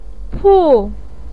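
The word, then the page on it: pu4: tone 4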